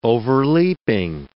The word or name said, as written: overleaping